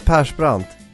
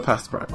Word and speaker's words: Persbrandt: Mikkel, how do you say this? Puss Brunt, and his character's name is Jakob. – Come again, who? – Okay, let's see if I can say that correctly. – Mikkel Persbrandt. – Yes, Mikkel Persbrandt.